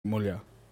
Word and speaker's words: Mulha: Mulha. Mulha is spelled M-U-L-H-A. Can you say Mulha?